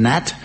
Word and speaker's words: gnat: English is a fascinating language containing many anomalies, including the redundant G in the word gnat, the unused K in knowledge, and the silent P in swimming baths.